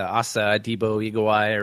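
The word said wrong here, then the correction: Hasa Diga Eebowai: or whatever. – Hasa Dega Igawai.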